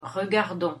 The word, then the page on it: regardons: French